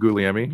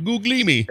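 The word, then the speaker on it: Gugliemi: Name is pronounced Guglielmi. – Guglielmi.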